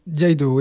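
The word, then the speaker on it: Iaidō: Yaido, oui. comment on prononce, excusez-moi On dit Yaido. Yaido, d'accord. Et donc, euh, ça veut dire, euh, si j'ai bien compris, dégainer en coupant.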